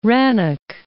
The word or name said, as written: Rannoch, Loch